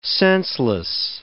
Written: senseless

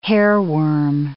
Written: hairworm

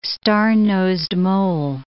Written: star-nosed mole

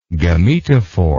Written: gametophoric

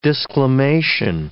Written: disclamation

